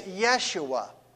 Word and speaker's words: Yeshua: But to those who were gathered in this synagogue service, he is Yeshua. That's how they would have pronounced his name, his Jewish name, Yeshua.